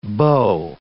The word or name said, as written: beau